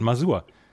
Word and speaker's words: Masur: Und ich, der ja nun wirklich sehr, sehr viel Wert legt drauf, habe irgendwann gesagt: jetzt lass gut sein, das wird so gesprochen, wie es geschrieben wird, Masur.